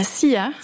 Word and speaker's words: La Silla: Et vous êtes même parti en 2012 au Chili pour 19 nuits d'observation sur le télescope suisse à la SILLA. – À la SIA.